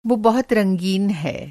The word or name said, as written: रंगीनी